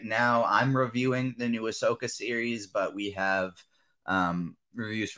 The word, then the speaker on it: Ahsoka: I can never pronounce that. Wait, is that Ahsoka? Is that how you pronounce the Star Wars? – Ahsoka. – Ahsoka, okay.